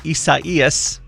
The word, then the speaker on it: Isaias: Watching tropical storm Isaias, that's how you say it apparently, is gaining strength.